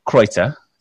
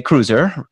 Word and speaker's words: Kreutzer: Robert Kreutzer. Did I pronounce it right with the German pronunciation? – Yeah, perfect. – Wow, look at that. Or as Americans would say, cruiser.